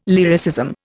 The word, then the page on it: lyricism: Indian